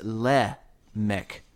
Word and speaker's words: Lamech: Now, this is spelled the same way in my Hebraic Roots version as it is in King James, so it looks like Lamech, but it's actually pronounced Lamech, Lamech.